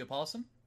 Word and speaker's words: opossum: About the opossum? – It's opossum. It's just a different way to spell it, but the O is silent anyway.